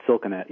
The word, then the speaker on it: Silkenat: Is the T sounded or is it silent? – Yeah, it sounded Silkenat, yep.